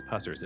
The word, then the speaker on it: Pusser's: And you said it correctly, by the way. It's Pusser's. It's not Pusser's.